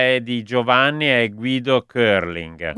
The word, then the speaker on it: curling: Sì, perché si pronuncia Carling.